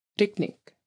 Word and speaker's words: rigning: So the way it's spelled is R-I-G-N-I-N-G. So this is an interesting one because the R at the beginning it's rolling a bit. So drikning.